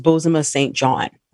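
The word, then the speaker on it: Bozoma Saint John: And that person was Bozema St. John.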